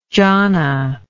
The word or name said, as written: johnna